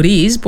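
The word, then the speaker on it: rizz: Ως ουσιαστικό και γοητεύω ή αποπλανώ ως ρήμα, το ρύζ που προφέρεται όπως ακριβώς σας το είπα, γράφεται με RI και 2Z και έχει τη ρίζα του στο χάρισμα.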